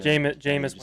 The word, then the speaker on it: Jameis: Jameis Winston. I pronounce like a soccer player. Jameis Winston? Okay. Yeah, Jameis Winston.